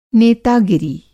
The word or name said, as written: नेतागिरी